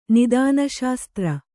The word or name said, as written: ನಿದಾನಶಾಸ್ತ್ರ